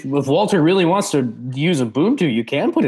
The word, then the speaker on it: Ubuntu: Yeah, it's Ubuntu.